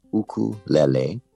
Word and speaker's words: ukulele: It's pronounced ukulele, and it's two Native Hawaiian words, uku, which means flee, and lele, which means jumping. So it's the direct translation is jumping flee, ukulele.